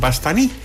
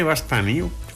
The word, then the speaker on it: Bastani: El problema es que lo que propone Bastani, creo que se pronuncia Pastani.